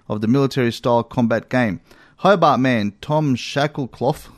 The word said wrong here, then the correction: Shackcloth: What is that? – Shackcloth, sorry. – hanger. Oh, how do you spell it? – S-H-A-C-K-C-L-O-T-H.